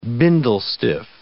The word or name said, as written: bindle stiff